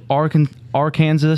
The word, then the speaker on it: Arkansas: 42, Arkansas. Is that how you say it? – Yes.